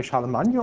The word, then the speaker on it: Charlemagne: So this is Charlemagne's, how do you say Charlemagne or Charles the Great? – Charlemagne.